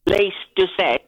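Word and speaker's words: Lyse Doucet: Now, her name's Lise Doucette. – Oh, thank you for the pronunciation.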